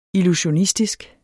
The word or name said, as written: illusionistisk